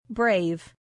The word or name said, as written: brave